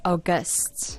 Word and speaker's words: august: I always thought that when it's used in this way that you're describing, it's August. – August. – He had an August temperament. – I mean, here it says the pronunciation is aw as in A-W, and then gust.